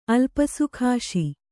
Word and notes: ಅಲ್ಪಸುಖಾಶಿ: Kannada

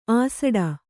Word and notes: ಆಸಡ: āsaḍa

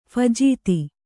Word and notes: ಫಜೀತಿ: phajīti

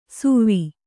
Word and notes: ಸುವ್ವಿ: suvvi

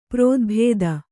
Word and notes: ಪ್ರೋದ್ಭೇದ: prōdbhēda

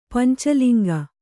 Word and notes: ಪಂಚಲಿಂಗ: kannada; panca liŋga

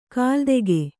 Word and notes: ಕಲಾದಕ: kalādaka

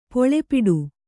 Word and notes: ಪುಲಿಪಟು: puli paṭu

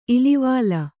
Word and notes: ಇಲ್ವಲ: ilvala

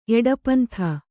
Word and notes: ಎಡಪಂಥ: eḍapantha; Kannada

